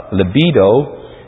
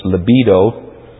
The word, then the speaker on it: libido: Freud taught that the drive for sexual gratification, called libido, and I've been reminded that I pronounce it two different ways this morning, so it's libido. I'll say it several times so we can put it right in the tapes. Libido.